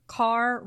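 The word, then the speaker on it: Kerr: Mayer, head of MGM, billed her as Car Rhymes with Star.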